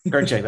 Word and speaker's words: Kirchick: I said that right, Kirchick? – Kirchick, that's right.